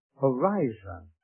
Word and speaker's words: horizo: P-R-O. Pro. That's the Greek word for pre, meaning beforehand. Pro. H-O-R-I-Z-O. Pronounced horizo.